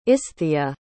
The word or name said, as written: isthia